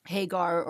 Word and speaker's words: Hagar: I'll say her name in Arabic and in English, you know, Hagar or Hajar, as we say in Arabic,